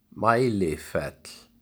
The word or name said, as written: Mælifell